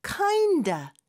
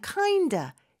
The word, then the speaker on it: kinda: 하나의 단어로 그냥 인식을 해야 된다라고 해주셨는데 실제로 발음을 할 때도 kinda, kinda of의 F 발음 빼고 그냥 kinda 이렇게 축약해서 말하는 경우도 굉장히 많습니다.